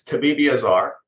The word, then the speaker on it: Tabibiazar: So it's correct. It's Tabibiazar. And Tabibi means doctor, and Azar means fire.